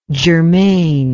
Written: germaine

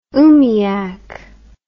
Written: oomiac

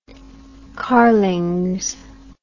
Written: carlings

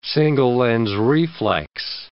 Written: single-lens reflex

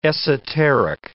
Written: esoteric

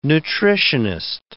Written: nutritionist

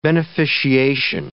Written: beneficiation

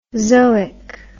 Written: zoic

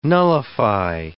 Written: nullify

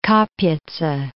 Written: Kapitsa

